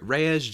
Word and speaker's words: Reyes: Follow me at Javier Reyes, JavierReyes, R-E-Y-E-S.